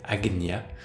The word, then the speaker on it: Ajna: Sixième chakra appelé Ajna, prononciation plus, plus correcte serait Agnya qui est quelque part une autre, euh, qui appartient quelque part à une autre euh, catégorie.